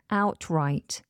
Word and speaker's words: outright: And it's the adverb outright. Outright. How do we spell it? O-U-T-R-I-G-H-T. Outright. You might hear that middle T dropped. So we have outright, outright. I dropped both T's then.